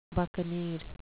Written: buccaneer

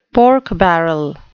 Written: pork barrel

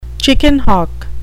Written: chicken hawk

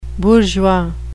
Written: bourgeois

